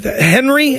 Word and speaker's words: Henry: Henry? How do I say that, Roadhouse? – I think you had it right. I think you were on the right track there.